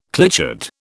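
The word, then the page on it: clichéd: British English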